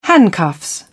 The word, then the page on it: handcuffs: British English